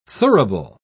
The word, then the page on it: thurible: British English